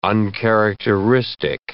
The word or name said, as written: uncharacteristic